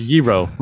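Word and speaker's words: Gyro: I didn't say hero might. It's, it's pronounced Yero.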